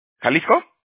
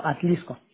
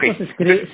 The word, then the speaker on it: Atlixco: ¿Jalisco? – No, Atlixco. – Atlixco, ok. Atlixco. Atlixco, ok. – Atlixco se escribe con X.